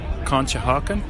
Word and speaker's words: Conshohocken: And also, now I'm hoping that I'm pronouncing this right, Conshohocken. – Yep. Very good.